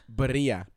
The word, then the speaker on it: birria: And this is totally a ripoff of the how do you pronounce it? Birria? – Birria. – Birria. Thank you.